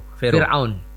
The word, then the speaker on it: Fir'aun: Bukan Pharaoh. Farao. Bacanya apa? – Fir'aun. Ya, Fir'aun. Ya.